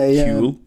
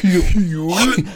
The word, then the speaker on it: Huel: Fuel and then take the F and put a H. – I know by Huel. Overpronounce the H normally. – Huel.